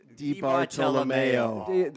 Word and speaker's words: DiBartolomeo: Dominick DiBartolomeo.